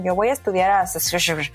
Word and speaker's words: Saskatchewan: Saskatchewan, Saskatchewan. Muy cercano, muy cercano, muy bien. Vi lo que trataste de hacer, A ver, ¿cómo se dice tú? – Bueno, yo digo que es Saskatchewan. Saskatchewan.